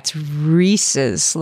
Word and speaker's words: Reese's: It's Reese's. Like, it rhymes with pieces.